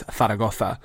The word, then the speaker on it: Zaragoza: Zaragotha, Zaragotha is like Mike Tyson's dream or Mike Tyson's nightmare of a place to pronounce because it's Zaragotha. It's proper.